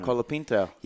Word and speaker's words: Colapinto: Colapinto.